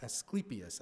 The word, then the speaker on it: Asclepius: Asclepius, as it should be pronounced, Asclepius, I keep getting that wrong.